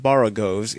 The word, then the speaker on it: borogoves: Again, the first O in borogoves is pronounced like the O in borrow. I have heard people try to give it the sound of the O in worry.